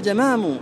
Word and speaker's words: جمام: إذن الجمام بالفتح بمعنى الراحة. قال في منحة المليح: وانطق بفتح الجيم قل جمام، اللحن في كلامنا كلام، إذا أردت الراحة فقل جمام بفتح الجيم.